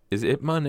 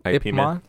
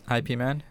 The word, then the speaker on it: Ip Man: Yes, it's pronounced Ip Man. – I don't believe But everybody in says IP Man.